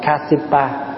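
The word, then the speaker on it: Kāśyapa: Pháp Hòa nói ví dụ như ngài Ca Diếp, Ca Diếp là tiếng Phạn đọc là Kasipa.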